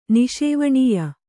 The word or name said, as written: ನಿಷೇವಣೀಯ